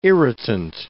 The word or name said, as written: irritant